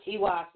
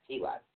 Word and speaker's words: Teiwaz: Okay, now TWAS is spelled T-E-I-W-A-Z, but it's pronounced T-E-E-W-A-H-Z. Okay, that's TWAS.